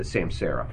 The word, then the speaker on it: Samsara: Do we think that the legislative office and the comptroller's office, which right now does have access to, is it called Samsara? Am I saying it right? – Samsara. Correct.